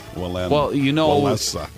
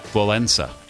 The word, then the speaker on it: Wałęsa: Where is the N in Wałęsa? – Wałęsa. There, because, not everybody speaks English and pronounces consonants the same way. Lech Wałęsa.